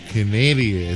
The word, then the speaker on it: Kinaeda: The name of this group is called Canadia. It's spelled K-I-N-A-E-D-A.